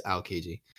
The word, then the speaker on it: Aokiji: I just know because of the way that Aokiji, and that's how you say his name, right? It's Aokiji, mm-hmm.